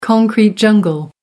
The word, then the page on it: concrete jungle: American English